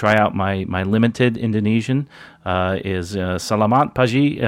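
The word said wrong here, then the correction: selamat pagi: Uh, did I do that right, Len? I hope I said close. Uh, salamat pagi. Pagi.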